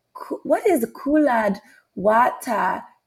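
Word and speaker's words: Cullud Wattah: So I heard about this show and it's spelled C-U-L-L-A-D. The second word, W-A-T-T-A-H. And I was telling my boyfriend, I was like, what is this? What is Coolad Wata? He was like, it sounds like colored water.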